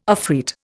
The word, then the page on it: afreet: Indian